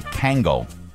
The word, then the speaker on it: Kangol: It's a Kangol.